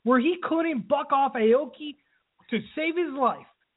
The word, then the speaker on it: Aoki: Aoki. – Say it right, please. Aoki!